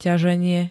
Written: ťaženie

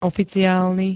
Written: oficiálny

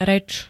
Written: rec

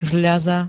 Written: žľaza

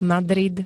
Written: Madrid

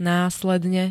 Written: následne

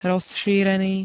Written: rozšírený

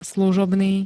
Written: služobný

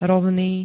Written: ROVNÝ

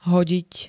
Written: hodiť

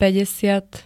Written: päťdesiat

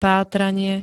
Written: pátranie

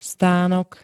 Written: stanok

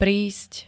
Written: prísť

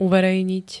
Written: uverejniť

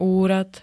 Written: úrad

